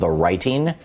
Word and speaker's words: The Righting: We had the guy who's the founder of The Righting, and that's spelled without the W. T-H-E-R-I-G-H-T-I-N-G.